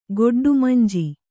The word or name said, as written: ಗೊಡ್ಡುಮಂಜಿ